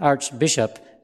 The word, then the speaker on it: archbishop: A-R-C-H is pronounced like a K, arch, when it comes before a vowel, archangel. But when A-R-C-H comes before a consonant, as an archbishop, then it's pronounced A-R-C-H, arch.